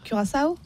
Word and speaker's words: Curaçao: Donc comment on prononce Curaçao, Clara? – Curaçao. – Curaçao. – Curaçao. – Ah oui, Curaçao, j'aime bien.